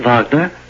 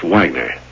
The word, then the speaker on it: Wagner: Isn't that Wagner? – No, it's pronounced Wagner. – Oh. Well, I... – You could pronounce it Wagner.